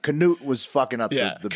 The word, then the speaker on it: Cnut: I guess it's technically pronounced Canute, but it's spelled C-N-U-T, so that's C-Nut to me.